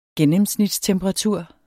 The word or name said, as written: gennemsnitstemperatur